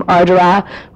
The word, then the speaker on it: Ardara: I myself am from Ardara, which is spelled A-R-D-A-R-A, and most Americans would say Ardara, and Glenties would be pronounced Glenties because it's G-L-E-N-T-I-E-S, and it's Donegal, not Donegal, but that's okay.